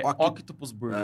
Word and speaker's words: Octopus Burger: O, a, a pronúncia certa é Octopus. Como é que é? é? Octopus Burger. É Octopus Burger.